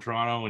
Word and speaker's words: Toronto: No, you say Toronto. Like, you don't spell it, you don't sound out the second T, where he Toronto.